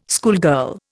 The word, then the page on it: schoolgirl: Indian